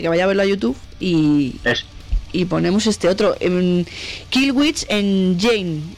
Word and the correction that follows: Killswitch Engage: ¿En Jage? ¿Pronuncio bien? En Jage. – Kill Switch Engage, Rose of Setting.